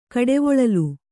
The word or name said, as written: ಕಡೆವೊಳಲು